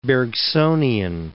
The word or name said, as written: Bergsonian